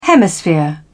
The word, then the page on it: hemisphere: British English